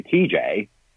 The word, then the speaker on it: Teejaye: But I officially changed my name a few years back from my stage name being TJ, which is spelled T-E-E-J-A-Y-E.